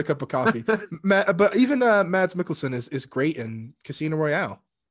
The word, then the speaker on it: Mads Mikkelsen: Yeah, I mean, and go back to Casino Royale, Mars Mouth Milkins, I ruined his name. – Lars! – It's Mads. I know I'm so bad at names. Pronounce it for me. You're better at it. – Mads Mikkelsen.